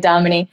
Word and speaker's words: Dominie: Don't be shy to ask me how to spell my name or how to pronounce it. I get that a lot. It is actually Dominie.